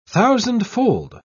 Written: thousandfold

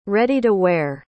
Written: ready-to-wear

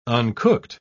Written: uncooked

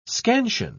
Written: scansion

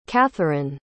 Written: catheryn

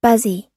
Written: buzzy

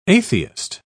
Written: atheist

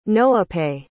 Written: noapeh

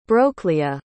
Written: brocleah

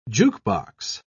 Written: jukebox

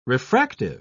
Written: refractive

